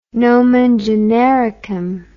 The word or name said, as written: nomen genericum